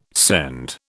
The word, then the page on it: send: British English